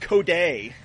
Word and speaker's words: Cody: Because it's a lot of times it's the wrong emphasis on the wrong syllable, or it's wrongly spelled, wrongly pronunciated. Like come on, people, it's pronounced Coday.